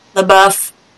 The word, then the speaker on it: LaBeouf: Shia LaBeouf, or however you say his name. – LaBeouf.